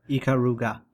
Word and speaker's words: Ikaruga: Ikaruga or Ikaruga? – Ikaruga. – Ikaruga. – I did pronounce it correctly for 10 years.